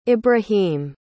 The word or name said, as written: ebrahim